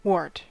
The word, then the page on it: wart: American English